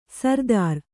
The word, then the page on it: ಸರ್ದಾರ್: sardār